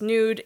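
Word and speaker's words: NXDE: The title track is Nude. Interestingly enough, it is spelled with an X instead of a U, but it's still pronounced the same way.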